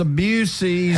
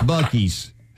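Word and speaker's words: Buc-ee's: Everybody wants a Busey's. – Has right you, there. – I mean, somebody right now is thinking, those idiots don't know it's Bucky's. – Oh, no. You guys have been saying it wrong.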